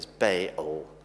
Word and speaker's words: Baal: And what you're meant to do is just sort of slightly separate the two A's, Baal.